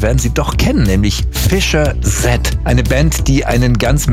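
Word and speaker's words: Fischer-Z: Ganz einfach, die Band war sehr lustig drauf und hat sich gedacht, wir nehmen einen Namen, der so ähnlich wie Fish's Head klingt. Ausgesprochen Fischer Z, ja, also Fishkopf.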